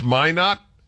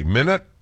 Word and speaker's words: Minot: It's pronounced Minot, and what did I say? Minute? Well, I now feel I need to apologize.